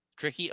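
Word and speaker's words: Krikke: How do you pronounce the last name of the new Iowa center? – Ben Krikey. – Krikey? Okay. Very good half-court player.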